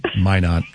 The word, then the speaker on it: Minot: Minot.